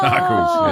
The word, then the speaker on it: Knuckles: Knuckles. Yeah. That does, it's a silent K, but it does count.